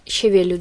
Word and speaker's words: щавель: Я вот знаю, что правильно щавель, ударение на «е», после второй слог.